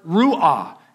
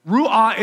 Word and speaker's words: ruah: In verse 2, where it says the Spirit of God, that word Spirit is the Hebrew word ruah. It's R-U-A-H, ruah. And I'm probably not pronouncing that exactly how the theologians would.